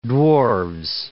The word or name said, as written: dwarves